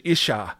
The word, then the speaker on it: Isha: Ish and Isha, I think, are the words that are used there. Ish and Isha, I think, is the better way to pronounce them.